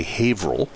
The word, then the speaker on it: Haverhill: But basically, in Haverhill, Massachusetts, which is spelled Haverhill, but we say Haverhill,